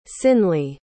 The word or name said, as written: sinley